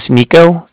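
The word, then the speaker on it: Niko: And my name is Nico. That's N-I-K-O. I'm calling with the Bank of Cardiff. And that's spelled C-A-R. D-I-F-F, as in Frank.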